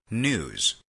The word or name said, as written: news